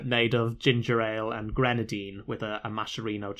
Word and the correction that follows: maraschino: cherry. Or a maraschino cherry. I always say that wrong.